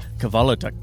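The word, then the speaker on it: Kvelertak: Kelvertech. – And if you listen to the pronunciation, which I did online just to be sure, it's Kvalotech.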